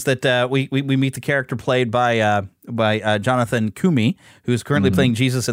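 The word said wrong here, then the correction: Roumie: Did I say it right? – I thought it was Rumi. – Is it Rumi? Let me look him up again here. It's on my page. Yeah, it is Rumi.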